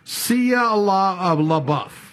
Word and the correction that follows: Shia LaBeouf: Shia LaBeouf. – Sounds good. Shia LaBeouf.